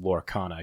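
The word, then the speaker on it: Lorcana: I, I thought it was just Loracana because there's no A after the R there.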